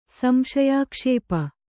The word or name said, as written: ಸಂಶಯಾಕ್ಷೇಪ